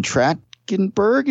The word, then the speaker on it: Trachtenberg: Dan Trachtenberg, am I pronouncing that right, Marco? – Yeah, that sounds right.